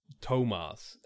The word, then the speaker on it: Thomas: Like, there's a character called Thomas, and, you know, it's spelt T-H-O-M-A-S, Thomas. And, yeah, he pronounces it Tomas, and I'm like...